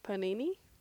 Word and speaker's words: panini: They have a giant, like, Thanksgiving turkey sandwich on a, like, a penne bread, I think that's how you say it, maybe. – Panini? – Yeah, panini, there you go.